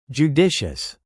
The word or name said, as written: judicious